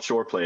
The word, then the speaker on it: Shoreplay: It's called Shoreplay. It rhymes with foreplay.